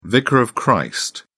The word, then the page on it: vicar of christ: British English